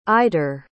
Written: idurre

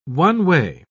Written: one-way

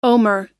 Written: Omer

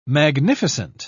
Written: magnificent

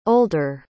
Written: older

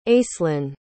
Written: aislin

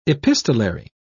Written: epistolary

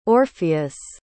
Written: orpheus

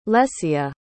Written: lecea

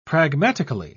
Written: pragmatically

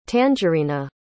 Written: tangerina

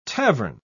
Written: tavern

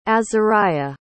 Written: azariah

